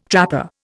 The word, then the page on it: trapper: Indian